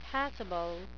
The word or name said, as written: passible